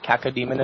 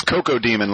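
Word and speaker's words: Cacodemon: Are we pronouncing that correctly? – Kaka. – What demon. Is it? – Dread Kaka Demon. – How do you spell it? – C-A-C-O Demon. – I don't think so. – It's Coco Demon, like Coco Crisp.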